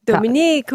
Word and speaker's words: Dominique: Je to francúzska, z ktorou sa ale chvála Bohu budem ako tak po anglicky zhovárať a dúfam, že správne vyslovím jej meno. I hope I will pronounce your name properly, Dominique. – Dominique, oui, bonjour.